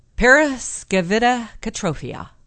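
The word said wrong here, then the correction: paraskevidekatriaphobia: Periskevidacataphobia. Periskevidacataphobia.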